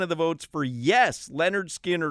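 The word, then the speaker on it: Lynyrd Skynyrd: There's a Leonard Skinner album that's called, it's pronounced Leonard Skinner.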